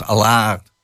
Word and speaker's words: Allard: Alleen alle klinkers werden lang uitgesproken. Dus dat was Allard.